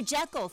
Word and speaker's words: Jekyll: Now, I totally butchered that, didn't I? Stay tuned, y'all. Dr. Jekyll, thank you. It's Dr. Jekyll and Mr. Hyde.